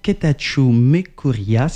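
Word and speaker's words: Getatchew Mekurya: c'était Akalewebe de Ketachu Mekuria, c'est comme ça, je prononce bien ? – Oui.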